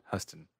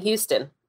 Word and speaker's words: Huston: I'll never pronounce her last name correctly, according to Huston. – Huston. – Because we were saying Houston and we got in trouble, both of us.